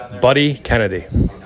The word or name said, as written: Buddy Kennedy